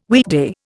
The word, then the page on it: weekday: Indian